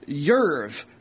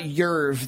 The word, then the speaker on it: Jerv: He spent the longest his longest career stint was with a Norwegian second division club called Jerv, which means Wolverine. So their actual club name is the mascot. Plus, I just love something about Jerv, that soft J.